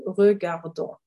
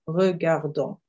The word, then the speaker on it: regardons: the ending is ons and it's pronounced on. So we've got a silent S again. So nous regardons. We watch, we are watching. Nous regardons.